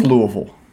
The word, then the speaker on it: Louisville: You got to say it. – I think that's how you say it. – It is Louisville. – Louisville. – No, I hear you say Louisville or Louisville, I'm going to slap you across the beezer.